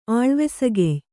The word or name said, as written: ಆಳ್ವೆಸಕೆಯ್